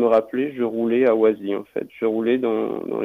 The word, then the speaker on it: Oisy: s'appelle Oisy. – Oisy.